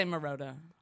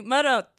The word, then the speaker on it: Marotta: People say Marotta. – Well, what's right? – Well, if I was like corny and like, saying an Italian pronunciation, Marotta. – I would be like, oh, no, you mean Lily Marotta. – Marotta. – I can't roll my L's.